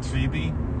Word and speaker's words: Phoebe: Phoebe? – Yeah. – Yeah, the PH for some reason makes an F sound.